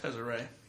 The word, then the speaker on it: Tezzeret: He reckons it's too much like is it Tezzeret or Tezzeret? – Tezzeret.